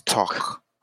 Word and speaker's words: talk: In English, we don't pronounce the L in talk. The L is silence. It's not talk. It's talk.